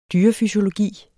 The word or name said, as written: dyrefysiologi